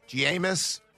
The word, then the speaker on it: Jameis: That might have been when Jameis had just kind of come on the scene, and he's like, Jameis. – Jamias. – Right. He was trying. Give me that one again. Jameis. – It's Jamison Winston. – Because that's spelled J-A-M-E-I-S.